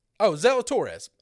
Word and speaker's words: Zalatoris: Zalatoris.